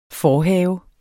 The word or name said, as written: forhave